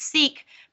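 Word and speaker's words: Seeq: Okay, so I'm talking today about Seek protein, but it's spelled weird because it's Seek, but it's with a Q. So it's S-E-E-Q.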